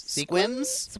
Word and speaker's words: Sequim: Squims? – Squintum? – It's spelled S-E-Q-U-I-M, but he says it Squim, Washington.